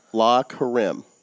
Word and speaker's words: La Carême: Tell me if I pronounce this wrong. La Carême. – I believe that's correct.